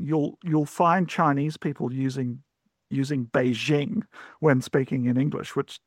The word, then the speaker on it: Beijing: Now, in fact, that je sound doesn't exist in Chinese. So you'll find a lot of people referring to Beijing as Beijing, and you'll find even native speakers of Chinese now using that Beijing pronunciation, even though that's just the jing of jingle bells. That's a sound that we have in English. So Beijing is, it's like jingle bells, right?